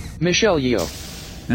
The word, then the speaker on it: Michelle Yeoh: Michelle Yeoh.